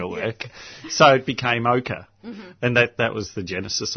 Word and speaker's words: OCA: And it was spelt O-C-A with an emphasis on the A. So it was sort of ochre.